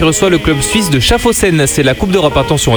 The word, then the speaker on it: Schaffhausen: Tu le dis bien, Schaffhausen.